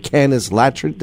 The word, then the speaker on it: Canis latrans: You've posted quite a great detailed information about Canis latrans.